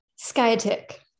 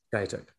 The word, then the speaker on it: Skiatook: Skiatook? – Skiatook. – Skiatook.